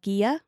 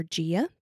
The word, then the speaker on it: Ghia: It's called Ghia or Ghia, depending how you say it, like GIF or JIF, that whole debate. I call it Ghia.